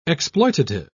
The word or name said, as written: exploitative